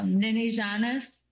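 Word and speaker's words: Niniijaanis: But hey, we have the CEO of Nini Janus. And I hope I said that right, because I've been practicing. Welcome to Native Roots Radio. – Bonjour. Nini Janus is correct, and that means loosely in Ojibwe, my child, and Nini Janus 101 is a social enterprise for Indigenous children.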